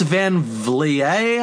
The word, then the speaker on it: Van Vliet: It's an interview that this guy, Chris Van Vliet, I believe his name is pronounced, V-L-I-E-T.